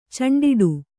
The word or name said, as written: ಚೆಂಡಾಡು